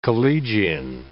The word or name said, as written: collegian